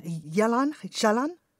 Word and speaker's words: Jalan Jalan: How do you pronounce it? Jalan? Jalan? – I think it's Jalan Jalan.